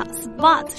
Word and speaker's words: spot: 好， spot，首先我们看一下这个发音，在英式里面它发的是 spot，美式发音发 spot，但是呢不管是英式还是美式啊，因为这个爆破音这个 p 放在 s 的后面呢，它不送气，所以发起来很像 b spot。